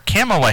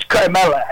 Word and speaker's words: Kamala: President Trump, let's talk about the choosing of Senator Kamala Harris as Joe Biden's... – I think it's Carmella. I think you're saying it wrong. It's Carmella. – No, Carmella. I don't think that's right, but let's call her Senator Harris for the moment.